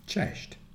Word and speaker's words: cześć: Cześć. – And it's not correct pronunciation. You should say cześć. – Cześć. – Now it's perfect.